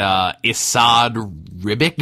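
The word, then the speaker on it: Esad Ribić: By Jason Aaron and Issad Ribic.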